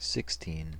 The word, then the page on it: sixteen: American English